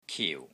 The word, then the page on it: KIU4: Cantonese